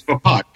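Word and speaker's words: Vaapad: Almost seems, you know, that by Mace going into his fighting form of Vapod or Vapid, I'm not sure how you pronounce it. – I believe it's Vapod.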